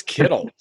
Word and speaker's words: Kittle: Ooh, does his name rhyme with Skittles? – It does. It's Kittles.